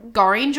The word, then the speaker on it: Gorringe: Goring or something. Then it's spelled G-O-R-R-I-N-G-E.